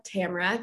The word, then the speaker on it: Tamara: My name is Tamara Anthony, pronounced like Tamara, Camera with a T.